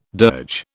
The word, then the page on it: dirge: British English